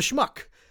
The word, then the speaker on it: schmuck: And then, of course, there is Schmooze and Schmaltzy and, you know, the Schlock, the Schmuck, which rhymes with Chuck Schumer and has many other affinities between him and that word.